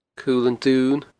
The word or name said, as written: Cul an Duin